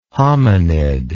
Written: homonid

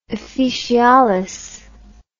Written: officialis

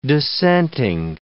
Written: dissenting

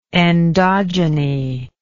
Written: endogeny

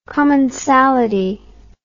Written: commensality

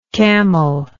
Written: camellike

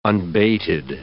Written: unbated

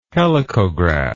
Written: helicograph

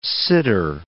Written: sitter

